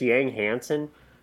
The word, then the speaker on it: Yang Hansen: Isn't it just Yang Hansen? – Yes, it is.